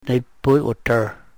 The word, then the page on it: nepuy we-trr: Yurok